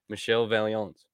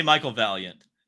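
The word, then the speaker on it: Michel Vaillant: You could also say Michael Valiant. – Michael Valiant. – Yeah, that's... – That's not Michael, though. That's Michel.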